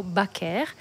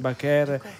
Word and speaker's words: Baker: Sì, la pronuncia infatti di questa attrice è Josephine Baker o Baker, e comunque anche negli Stati Uniti la chiamano con l'accento francese, alla francese.